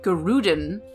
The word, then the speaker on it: guerdon: She promised the maiden that in recompense of her service, she would grant her such guerdon as she would wish, which is a reward or recompense. – How do you spell it? – Oh, it's not guerdon. I said it wrong. Guerdon. Guerdon? G-U-E-R-D-O-N.